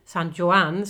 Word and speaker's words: San Juan: Vi jobbar, eh, vårt kontor ligger på eh, Calle San Juan, San Juan ska man uttala det, och det ligger precis bredvid Abaco i La Lonja.